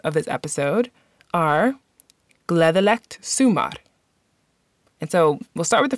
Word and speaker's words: Gleðilegt sumar: So the first part of the word is su, and the last part is mar. Sumar. You can see how both those words are spelled in the show notes. But one last time, the word is pronounced Gleðilegt sumar.